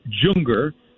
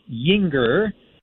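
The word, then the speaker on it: Juenger: Hayden Yinger looks like Junger because it's J-U-E-N-G-E-R, but pronounced Yinger.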